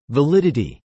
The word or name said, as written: validity